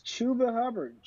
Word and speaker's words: Chuba Hubbard: Am I saying that right? – Yeah, it's Chuba Hubbard.